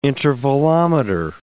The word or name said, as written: intervalometer